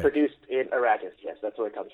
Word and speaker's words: Arrakis: Duke Leto is the ruler of an ocean planet, Caladan, accepts a relocation to a harsh desert planet of Arrakis.